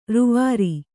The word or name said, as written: ರೂವರಿ